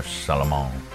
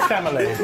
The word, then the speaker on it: Chalamet: It's Chalamet. – It's not Chamolet. – Wait, no. It's Chamolet. – All right. Timothée Chalamet.